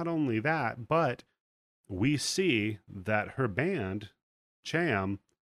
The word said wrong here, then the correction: CHAM: I'm not sure how to pronounce it, has broken. – Yeah, I think it's Cham. – Cham.